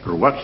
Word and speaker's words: ruah: Ruaj se escribe R-U-A-H, el Ruaj es el soplo de Yahvé.